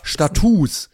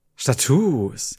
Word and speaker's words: Status: Es ist aber Status. – Es wird genauso geschrieben, aber mit ah. langem U ausgesprochen. Status.